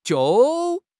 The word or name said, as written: jiu4